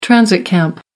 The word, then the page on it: transit camp: American English